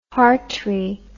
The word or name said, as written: hartree